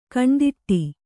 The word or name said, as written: ಕಣ್ತಡೆ